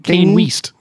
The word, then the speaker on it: Kanye West: It's Kane West.